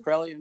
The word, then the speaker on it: Karelian: Nativist tradition, am I saying that right? – That is correct. It's Karelian nativist tradition.